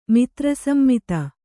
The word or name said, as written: ಮಿತ್ರಸಮ್ಮಿತ